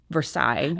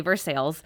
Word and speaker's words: Versailles: Is that, I mean, it's Versailles is what we think of, but we say Versailles.